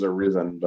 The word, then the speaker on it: Arizin: Ray Allen, Giannis, Carmelo Anthony, Nate Archibald, Paul Arzine. Charles Barkley. – I thought it was Arizin, but yeah.